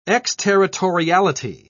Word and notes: exterritoriality: British English

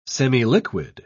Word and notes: semiliquid: British English

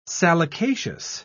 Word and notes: salicaceous: British English

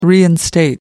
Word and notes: reinstate: American English

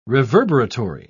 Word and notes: reverberatory: British English

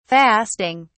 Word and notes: fasting: British English

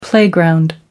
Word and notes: playground: American English

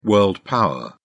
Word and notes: world-power: British English

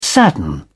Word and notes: sadden: British English

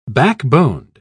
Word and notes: backboned: British English

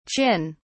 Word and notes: qin: British English

